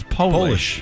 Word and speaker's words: Polish: Polish is the only word in the English language that changes its meaning and pronunciation when you capitalize it. – Yeah. – Polish becomes Polish.